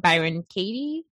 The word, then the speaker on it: Byron Katie: I think that's, I know Jill has, she introduced me to Byron Katie.